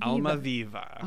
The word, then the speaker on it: Almaviva: And how do you say the Count Almaviva? – Almaviva.